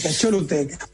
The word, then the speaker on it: Choluteca: ¿De qué manera las ideas de March nos pueden colaborar en aprovechar los aprendizajes de esta historia del puente del río Choluteca? Sí, lo dije bien. – Choluteca.